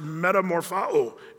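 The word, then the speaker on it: metamorphao (μεταμορφόω): It's pronounced metamorphao in the Greek, which means to change into another form, to transform, to transfigure.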